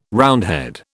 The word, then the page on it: Roundhead: British English